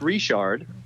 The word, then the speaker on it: Richard: Uh, but the name is actually pronounced Richard, uh, mm-hmm. and it is very French.